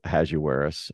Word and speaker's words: Ahasuerus: And we're going to right away be introduced to the king, who we usually say his name in English as Ahasuerus. I think that's a typical pronunciation that we give.